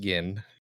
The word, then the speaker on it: Gin: Or Gin. Sorry, not Jin. I think he's pronounced Gin.